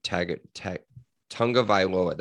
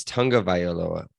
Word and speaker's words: Tagovailoa: Would you take the rest of Talia Tagovailoa? That's how you say it. Bam. That's it. I listened to enough. It's Tagovailoa. That's what it is. Tagovailoa.